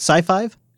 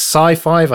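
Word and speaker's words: SiFive: Is that how you say it? Sci-Five? – Yes, Sci-Five, I think is how you pronounce it.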